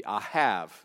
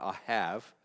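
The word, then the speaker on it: Ahav (אהב): And so the most common way of pronouncing this would be Ahav. The B on the end has almost more of a V sound, Ahav.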